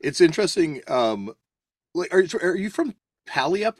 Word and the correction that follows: Puyallup: or Tacoma? So so yeah, it's it's called, it's pronounced Puyallup Oh, sorry, Puyallup. Yeah, sorry, Puyallup.